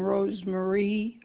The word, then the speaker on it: Rose Marie: First name is all one name, Rose Marie, R-O-S-E-M-A-R-I-E. Initial K, Warren, W-A-R-R-E-N.